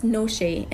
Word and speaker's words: Noshe: It's pronounced Noshe, and it comes from Noshe Jan, which means nourish your soul.